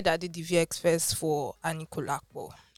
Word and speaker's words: Anikulapo: Abi, did I pronounce it well? Sorry to every Yoruba person. I'm not Yoruba. No, it's correct. It's... Yeah, no, the pronunciations are as simple as that. Anikulapo, just tiny. Those are just little, but it's correct.